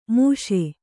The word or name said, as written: ಮಿಷ